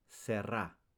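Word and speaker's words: tsere: This vowel marker is called serra and is pronounced e as in pet.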